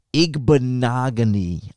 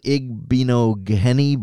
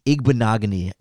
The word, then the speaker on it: Igbinoghene: There's too many letters in there, and it looks like an Igbinoghene, but Igbinoghene is how I've been told to pronounce that last name, and it would be a fun one to have to learn in Chicago.